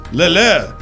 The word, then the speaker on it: Leclerc: Apparently it's a silent C, but I'm not a French guy. – All right, Charles Leclerc. – Leclerc. – Both C's are silent? – Just the second one.